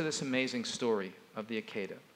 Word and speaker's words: Akedah: It's Akedah, really. It rhymes with cicada.